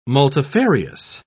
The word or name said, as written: multifarious